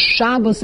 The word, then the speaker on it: Shabbos: But you don't need to come on to that for Shabbos, because Shabbos is the correct way to pronounce Sabbath in Yiddish.